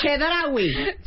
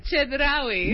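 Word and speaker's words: Chedraui: Uh, Chiridui Select. – Cero. Muy mal. Muy mal. You are Russian. You should be able to pronounce the CH. Chedrawi. Chedrawi. – Muy bien. Chedrawi Select.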